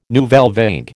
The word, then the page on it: nouvelle vague: American English